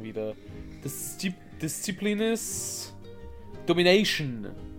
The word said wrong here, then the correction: Disciples: Ah, Disciples?